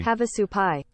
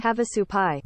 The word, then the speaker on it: Havasupai: It's the Havasupai. – Havasupai. Havasupai? – Havasupai.